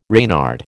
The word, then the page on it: Reynard: American English